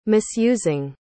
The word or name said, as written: misusing